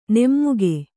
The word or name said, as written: ನಮಕ